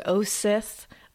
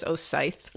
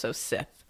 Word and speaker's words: Osyth: It is Osith, like the Star Wars villains, I learned doing, because it's spelled Saint Osyth, so I thought it was Osyth for a while, but it's Osith.